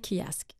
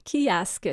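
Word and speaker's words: Keeyask: Kiask.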